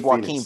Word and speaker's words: Joaquin: Is it Joaquin or Joaquin? Yeah, it's Joaquin Phoenix.